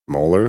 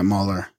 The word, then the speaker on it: Moller: Moeller, like the tooth? – No, M-O-L-L-E-R. – Yeah, that's not like the tooth. That's Moeller. – Yeah, Moeller.